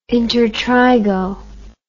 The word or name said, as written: intertrigo